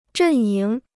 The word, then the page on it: 阵营: zhèn yíng